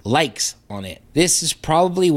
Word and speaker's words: Lyxe: It is called Likes. That is spelled L-Y-X-E.